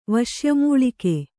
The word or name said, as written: ವಶ್ಯಮೂಳಿಕೆ